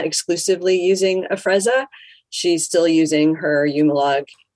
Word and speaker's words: Afrezza: Is it Afrezza? – You said it perfectly.